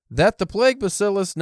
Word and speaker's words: bacillus: The guy in the video, I swear, said T, like Batalus. – Maybe. Wait, how do you spell it? – B-A-C-I-L-L-U-S. – Bacillus. – Bacillus. But the guy definitely with the French accent said it with the T. And I was like, that doesn't sound right.